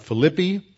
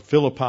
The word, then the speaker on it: Philippi: Paul is in uh, uh, Philippi are Philippi, as we pronounce it in English, Philippi in, in, Greek, located here.